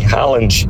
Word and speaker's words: Haaland: A pronúncia do nome Haaland, de fato, é com O. Ele pronuncia dessa forma. Os noruegueses pronunciam dessa forma.